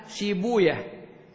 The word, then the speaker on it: سيبويه: وعند أهل الحديث أن الواو المكسورة بعدها ياء ساكنة والهاء المكسورة والمبنية على الكسر في مثل سيبويه، أهل الحديث فرارا من معنى الصراخ الذي تفيده كلمة وي هذه ينطقون سيبوية ونفطوية على هذا النحو.